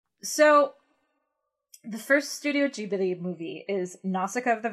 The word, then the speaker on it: Ghibli: It's Ghibli. – So we looked up, we've got the distribution history of Studio Ghibli. No, I'm saying it wrong. – I think it might even be Ghibli. Ghibli.